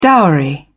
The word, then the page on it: dowry: British English